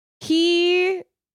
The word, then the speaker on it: hiraeth: It's a Welsh word. It's pronounced Hiraeth. – How's it spelled? – Hiraeth. – H-I-R-A-E-T-H, pronounced Hiraeth.